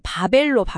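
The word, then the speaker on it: 바벨로 (בבל): 히브리어로 그 도시의 이름은 바벨로 발음이 됩니다.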